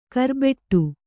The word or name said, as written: ಕರ್ಬೆಟ್ಟು